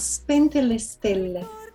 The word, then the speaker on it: Spente le stelle: Spente le stelle? Am I close? – Very close. Spente le stelle.